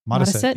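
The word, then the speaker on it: Modesitt: Modisit Jr. Did I say it wrong? – Modisit. Yeah, Modisit. – You said it right.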